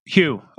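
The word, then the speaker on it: Huw: But the guy's name was Wu, is that right? – Hugh. – Okay, Hugh. – He's Welsh. That's why it's spelt funny.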